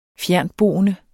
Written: fjerntboende

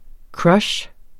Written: crush

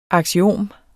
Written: aksiom

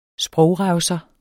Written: sprogrevser

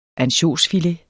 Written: ansjosfilet

